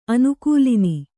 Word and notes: ಅನುಕೂಲಿನಿ: anukūlini

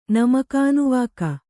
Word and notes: ನಮಕಾನುವಾಕ: Kannada

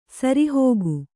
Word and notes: ಸರಿಹೋಗು: sari hōgu

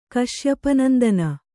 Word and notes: ಕಶ್ಯಪನಂದನ: Kannada

